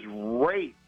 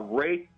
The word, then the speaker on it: Rath: So my name is Wraith, and it's spelled R-A-T-H. Wraith Cyrus. – Now, is it Wraith like a ray of sun? – Well, it's tough.